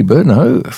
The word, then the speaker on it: Brno: That's spelled B-R-N-O, Brno is I think how it's pronounced, which is, of course, a city in eastern parts of the Czech Republic.